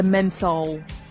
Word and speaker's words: menthol: It's not a mint, it's a horse mental eucalyptus. – Ah, yes, excellent. – Hang on, sorry. – Thank you, nurse. – I always say that wrong. It's a menthol.